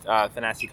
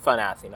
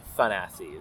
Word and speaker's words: Thanasi: There's much more some discussion recently about how it's Thanasi, not Thanasi, people say apparently, but Thanasi is the way to go, based on his own pronunciation of it and how Nick says it as well.